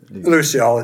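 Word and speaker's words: Luciole: Lucio, it's the French for firefly.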